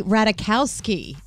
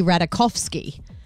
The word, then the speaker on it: Ratajkowski: Emily Ratajkowski. Completely wrong. It's Emily Ratajkowski.